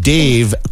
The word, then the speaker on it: Dave: So I've decided since my name, Dave, kind of rhymes with complain.